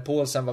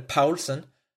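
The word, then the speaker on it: Poulsen: Ska ju sägas att Leipzig hade väl den första riktiga möjligheten att, att sätta 1-0 mm, i typ den fjärde minuten när Paulsen var framme, eller Paulsen. Eh, om man nu ska uttala det säkert korrekt på danska.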